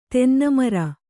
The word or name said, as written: ತೆನ್ನಮರ